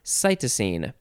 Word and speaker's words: cytosine: And let's see, let's look ahead to see if we can find out how to pronounce that. It is cytosine. So the cytidine is a nucleoside containing cytosine. Cool. Next we have cytokalasin. cytochalasin. C-Y-T-O-C-H-A-L-A-S-I-N.